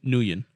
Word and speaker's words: Nguyen: It's Christine Nguyen. I think it's how you pronounce it. N G U Y E. Well, that's not how you pronounce it. – The Gucci Nguyen.